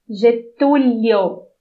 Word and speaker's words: Getúlio: You didn't pronounce the I. You just went. – You said Getulio, and I said Getulio. Do you hear the difference? – I don't think you said that originally.